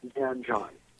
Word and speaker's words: Sanjan: Well, I am not a native Persian speaker, so I will not be pronouncing it correctly either, but I understand that I should be pronouncing it Sanjan.